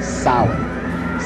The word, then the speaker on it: Samhain: name Sam Hayen or something like that. No. This is properly pronounced in the ancient Gaelic as Samhain.